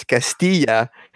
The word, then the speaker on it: Castilla: I told him, listen, you white man, it's Castilla. It's a Spanish word.